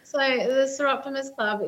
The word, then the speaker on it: Soroptimist: You are the president, and I'm going to butcher this, of the Soroptimist, is that right? Yes. Soroptimist International, yes.